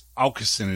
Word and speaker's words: Åkesson: But anyway, so we got Pontus, Sebastian, and Simon, and their last name is Aukesson. It has that A with a little circle, but it's pronounced more like an owl.